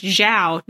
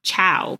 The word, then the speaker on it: Zhao: And it's Zhao, not Chow. Like, stop saying it wrong.